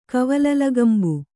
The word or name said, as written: ಕವಲಲಗಂಬು